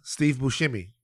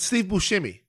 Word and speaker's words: Buscemi: What is it? Is it Buscemi or Buscemi? – Steve Buscemi. – Buscemi. – It's Steve Buscemi, and you should know how to say his name.